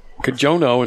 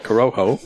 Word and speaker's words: Corojo: On episode 178, we had Kajono instead of Corojo.